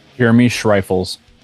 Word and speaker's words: Jeremy Schreifels: How do you say your last name? – Jeremy Schreifels. – So I was right.